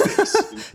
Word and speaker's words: bass: And my mom said, what's the bass? And I'm like, it's bass, mom. It's pronounced bass.